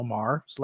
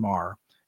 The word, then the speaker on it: Maher: Is a woman named Catherine, and I presume it's spelled exactly the same way as Bill Maher's last name. Mm. So I presume it's pronounced the same. So let's say Catherine Maher, M-A-H-E-R, Catherine Maher.